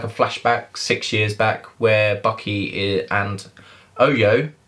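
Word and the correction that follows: Ayo: if that's how you say it. Um, I think it was Io.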